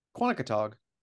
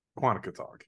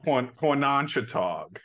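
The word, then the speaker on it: Quonochontaug: Since you are from Charlestown, how do you say Kwani full? – Quantico-tog. – Quantico-tog, yeah. Okay.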